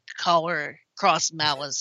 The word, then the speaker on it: Collar X Malice: We pronounce X's as cross around here. – Uh-huh. Busta Fellows is a good, more universal mystery otome like Collar Cross Malice so far.